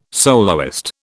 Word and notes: soloist: British English

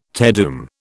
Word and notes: Te Deum: British English

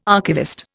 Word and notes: archivist: Indian

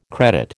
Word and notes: credit: American English

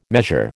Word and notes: measure: American English

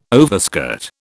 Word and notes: overskirt: British English

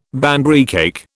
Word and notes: Banbury cake: British English

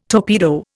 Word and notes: torpedo: Indian

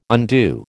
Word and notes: undue: American English

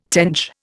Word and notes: tench: Indian

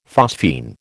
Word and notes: phosphine: American English

